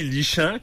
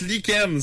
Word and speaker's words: lichen: Quelque chose qui se lit lichant, comme disait Marion. – Ben ouais, tu... – Pourquoi on le prononce lichen? C'est quoi le rapport? – Ça devrait être écrit comme L-I-K-E-N-N-E. – Lichen.